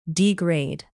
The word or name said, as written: degrade